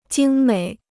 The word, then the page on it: 精美: jīng měi